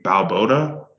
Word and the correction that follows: Bilotto: is that his Bilotto. – Name? Bilotto, Coach Bilotto.